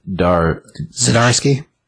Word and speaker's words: Zdarsky: Zdarsky. – Zdarsky? – Mm-hmm. – Isn't it Zdarsky? – No, the Z is pronounced in it.